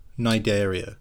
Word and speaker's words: Cnidaria: Are in the same phylum as jellyfish, Cnidaria. – Okay. – It's got a big C on the front. Just ignore it, and you're pronouncing it correctly.